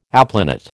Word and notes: aplanat: American English

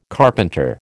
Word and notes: carpenter: American English

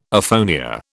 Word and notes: aphonia: British English

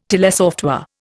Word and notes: telesoftware: Indian

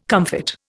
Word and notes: comfit: Indian

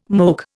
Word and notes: moke: Indian

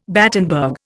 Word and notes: Battenberg: Indian

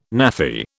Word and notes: Naffy: British English

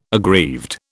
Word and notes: aggrieved: British English

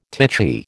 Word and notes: titchy: American English

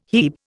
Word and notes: hebe: Indian

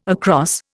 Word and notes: across: Indian

